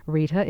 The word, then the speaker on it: Rita: Of course, you know my name is Rita Rochelle, and I'll spell that for you for our new listeners. Rita is R-I-T-A. Rochelle is R-O-C-H-E-L-L-E.